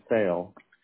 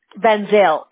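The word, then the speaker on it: Van Zyl: So it'll be V-A-N space Z-Y-L. – Okay. Benzil? Is that how you pronounce it? Or Zyle? – Benzil. – Benzil.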